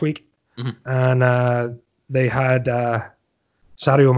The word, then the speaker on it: Sadio: So, yeah, you know, obviously Sadio is his favorite player, or should I say Sadio. We have to pronounce it properly. – Right, yeah, Sadio.